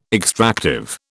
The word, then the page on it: extractive: British English